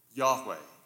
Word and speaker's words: Yahweh: YHWH, often pronounced, and here I'll only pronounce the name once to make the point, Yahweh.